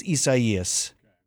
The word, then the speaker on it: Isaias: I believe it's Isaias is how they're saying it.